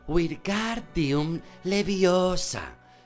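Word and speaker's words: Wingardium Leviosa: Wingargum Leviosa, gritó, agitando sus largos brazos como un molino. Lo estás diciendo mal. Harry oyó que Hermión lo reñía. Es Wirgardium Leviosa. Pronuncia gar más claro y más largo.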